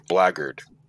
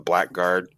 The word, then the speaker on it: Blackguard: Because, you know, one of the names that they always throw out is Blackguard. And everybody pronounces it Blackguard. It's actually Blackguard.